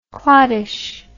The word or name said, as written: clottish